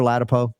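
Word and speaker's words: Ladapo: Did I say it right, Dr. Latipo? – Yes. Don't mess yourself up again. Just go with Latipo.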